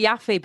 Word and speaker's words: Yaffe: We pronounce it Yaffe, but it is spelled Y-A-F-F-E. So I know that it doesn't spell how it sounds.